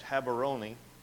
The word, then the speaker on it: Gaborone: So anyway, so they wanted to medevac me to Gaborone, which in Africa is pronounced Habaroni.